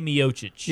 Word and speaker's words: Miocic: Stipe Miocic.